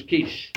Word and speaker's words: quiche: I told her I wanted a quickie, but the correct pronunciation was quiche. So you see, there's an old dad joke. – Yeah. – I made a right mistake at the date with my girlfriend last night. I asked her if she wanted a quickie, but it turned out the pronunciation was quiche. So it's a joke. – Yeah. – But because niche and quiche rhyme, and because it's a French restaurant, you can somehow manage to, and as I say, you get a double winner because...